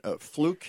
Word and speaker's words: Fluck: We are with Justin Fluck here. And by the way, that's spelled F-L-U-C-K, but it's pronounced Fluck, from Fluck Farms Chicago, a winner in the Chicago Excellence in Gardening Awards in 2019.